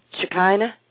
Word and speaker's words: Shekinah: Shekinah? – Yep.